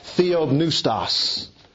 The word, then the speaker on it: theopneustos: The Greek word for inspired is a word pronounced theopneustos.